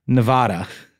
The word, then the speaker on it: Nevada: Y'all have been mispronouncing Nevada. I'm going to keep doing it to my dying day. – Nevada. – You have been saying Nevada, but it's really Nevada? Wait a minute. That doesn't make any sense. – What, did he capitalize? – He just put the emphasis on the duh instead of the va.